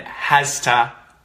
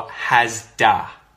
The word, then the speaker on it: has to: When we say this with quick pronunciation, quick connected speech, it turns into havta or havda and hasta or hasda. So the T can become a bit of a D sound.